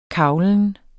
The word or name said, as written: kaglen